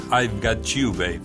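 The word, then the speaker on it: I Got You Babe: Pero si les parece, vamos con I've Got You, Babe.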